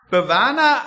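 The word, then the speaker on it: Bwana: Fufifu, Betonung im Suaheli auf die vorletzte Silbe. Also es dürfte richtig gewesen sein. Bawana Aliyafu Fuya.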